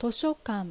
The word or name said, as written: Toshokan